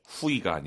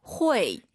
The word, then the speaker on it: 会: 후이가 아니라 우리 선생님 발음 다시 들어볼게요. – 회. – UI 사이에 넣어야 돼요. 회.